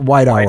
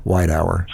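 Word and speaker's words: Weidauer: White, it's Hour. White Hour. – White Hour. – White Hour. It's W E I D A U E R. White Hour.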